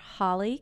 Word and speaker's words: Holly: My Twitter name is underscore Holly Cain, and Cain is spelled C-A-I-N. So it's underscore H-O-L-L-Y-C-A-I-N.